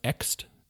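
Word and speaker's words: Ecsed: Which is, yeah, it's E-C-S-E-D, but it's pronounced Ext.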